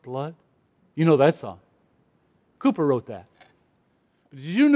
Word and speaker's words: Cowper: If you saw his name, you would pronounce it Cowper, C-O-W-P-E-R, but it's not pronounced that way. It's pronounced Cooper.